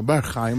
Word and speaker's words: Berghain: Claro, Berghain, mirá que bien pronunciado.